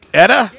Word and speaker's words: Eta: Eta? Yeah. Like Etta James? – Yeah.